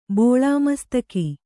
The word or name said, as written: ಬೋಳಾಮಸ್ತಕಿ